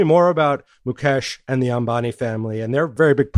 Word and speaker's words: Ambani: Now, the company is controlled by the Ambani family. Am I saying that right? – Right.